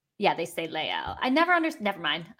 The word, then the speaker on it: Les Halles: I asked my French teacher specifically about this because there is a Les Halles in France. And I think they say Les Halles, even though you would think because the H is silent, they should pronounce the S by French rules, but they don't.